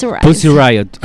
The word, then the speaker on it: Pussy Riot: Perdón, yo lo dije mal, ¿cómo es que se dice? – Pussy Riot. – Ah, ahora está.